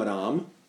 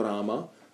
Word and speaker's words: Ráma: Potom, když zpíváme Hare Krishna Mahamantru, tak jsou určité autorizované jak bych řekl, odchylky, že například můžeme říct třeba jenom Rám místo Ráma, nebo Rámo místo Ráma, ale obecně se to nedoporučuje. Jo, je to, hindi výslovnost je Rám.